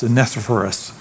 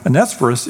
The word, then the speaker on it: Onesiphorus: And by the way, scholars disagree, I found out this week, on how to pronounce his name. They call him different things. But as best I can tell, it's Onesiphorus. But it says, may the Lord show mercy on the household of Onesiphorus.